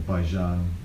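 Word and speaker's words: Baizhang: and they're students of a monk called Hyakujo, or Baijan in the Chinese pronunciation.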